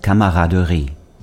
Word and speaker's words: camaraderie: The French pronunciation of camaraderie is camaraderie.